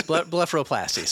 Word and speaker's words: blepharoplasty: Yes, blepharoplasty.